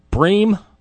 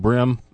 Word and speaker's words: Bream: Sid Bream from the Atlanta Braves. We had a question about his pronunciation of his name last week. I said Brim, but apparently it's Bream.